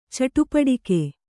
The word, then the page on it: ಚಟಪಟಿಕೆ: caṭapaṭike